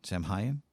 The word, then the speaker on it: Samhain: Samhain. – Samhain. – Something like that. – Yep. It's spelled with an M. – So they, phonetically, it is S-A-W-W-I-N.